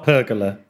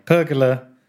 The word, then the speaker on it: pergola: But I also treated myself to I never know how to pronounce it, pergola. – Yes. – Not pergola. Pergola. Sounds like a coffee maker, doesn't it?